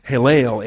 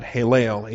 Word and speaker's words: Heylel: Hallel, H-E-Y-L-E-L, and that second E is a long E. That's why I put the macron over the top of that. Hallel, H-E-Y-L-E-L, macron over that second E, Hallel.